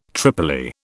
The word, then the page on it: tripoli: British English